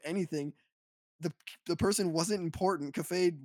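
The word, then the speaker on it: Kafei: is he turns a character called Cafe, which I think that's how you pronounce it. It's K-A-F-E-I. It's a weird word, weird name.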